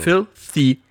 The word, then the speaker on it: filthy: Habe ich das TH eigentlich schön ausgesprochen? – Wunderbar. – Wenn ich also Filthy sage. – Filthy. Du musst etwas mehr spucken dabei.